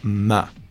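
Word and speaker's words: MØ: Así que su nombre artístico se pronuncia Ma, como si fueras una vaca, Ma.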